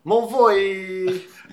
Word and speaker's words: Mulvoy: Although, of course, as Mulvoy would tell us, they all know. – Yeah, although in fairness, it should be pronounced Mauvoy. Mark Mauvoy is a legend in magazine circles.